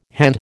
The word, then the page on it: hand: American English